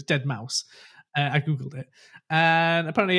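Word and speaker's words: deadmau5: He's like, Dead Mouse 5, but it's Dead Mouse.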